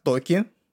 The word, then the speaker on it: Токи: И сегодня у меня в гостях Токи. Правильно же я говорю ударение? Правильно. Отлично кайф.